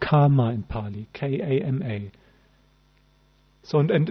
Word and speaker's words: kama: Kama, K long A-M-A, kama, that is sensual desires or the objects of sensual desires.